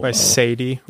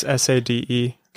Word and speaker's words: Sade: Sadie or Sade. It's Sade.